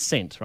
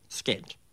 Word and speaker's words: scent: Remembering there's different ways to pronounce certain letters and that there's silent letters in English, which letter is actually silent in scent? Is it S or is it C? – Because you can have scent.